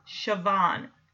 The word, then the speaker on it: Siobhan: It's S-I-O-B-H-A-N, and I looked it up, and it says it's pronounced Siobhan.